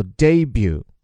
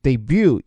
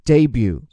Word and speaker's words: debut: 总之就是新人新事物的首秀，但是这个词的亮点还在于它的发音哈，一看就是法语词，最后面那个 t 字母不发音，而前面的重音叫做 debut。当然我看美音里啊，把它放在第二个音级 debut 也是对的，但请注意的是这个 e 字母啊，它发的不是 a 这个惯常的发音，而是在法语当中哈要发作 a，就这个 a 最后还要归到一上面去。